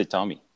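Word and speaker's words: Tomy: So they call me Tommy with one M, which doesn't make any difference in French. But then since I moved to the English world, like everybody's like, oh, do you say your name? So either way, I'm good with like when people ask you, what do you say? – I just say Tommy.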